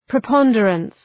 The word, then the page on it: preponderance: prı’pɒndərəns